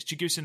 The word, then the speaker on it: Chigusa: So Chigusa, I hope it's Chigusa, I keep saying it wrong, it is Chigusa Nagoyo.